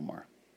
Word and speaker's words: Maher: Bill Maher.